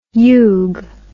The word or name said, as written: yug